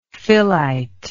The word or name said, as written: phyllite